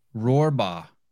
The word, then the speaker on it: Rohrbaugh: Rohrbaugh.